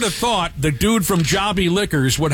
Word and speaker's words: Jobi: It's called Jobby Liquors, J-O-B-I. It's pronounced Jobby Liquors.